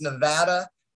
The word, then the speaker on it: Nevada: It's actually pronounced Nevada.